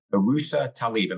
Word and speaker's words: Uroosa Talib: We have Dr. Uroosa Talib.